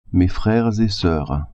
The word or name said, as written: freres et soeurs